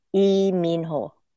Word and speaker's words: Lee Min Ho: But for Lee Min Ho, it's E. And then there is no short I sound in Korean. It's E and Min have the same vowel sound. E Min Ho.